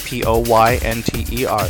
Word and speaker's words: Poynter: The name is spelled K E R R Y P O Y N T E R.